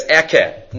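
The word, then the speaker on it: ekeh (אהיה): I read it as ekeh, not Aleph pronouncing it otherwise.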